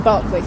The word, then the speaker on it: Berkeley: So Berkeley or Barclay, depending Barclay. – On how you want to say it. – How do you say it? – I say Barclay.